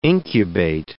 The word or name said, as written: incubate